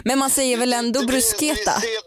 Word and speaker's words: bruschetta: Ja, just det, och där har vi den där H-grejen. Det blir bruschetta. – Okej. – Okay. Det är K.